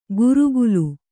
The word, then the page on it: ಗುರುಗುಲು: gurugulu